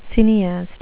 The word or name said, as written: cineaste